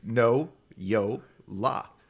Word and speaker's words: Noyola: My name is so phonetic. It's No-Yo-La. – Yeah. – It's literally the way it's spelled.